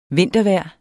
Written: vintervejr

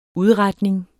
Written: udretning

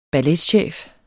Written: balletchef

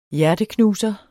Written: hjerteknuser

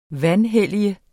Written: vanhellige

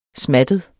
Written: smattet

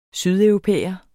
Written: sydeuropæer

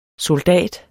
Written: SOLDATS

